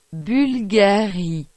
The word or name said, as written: Bvlgari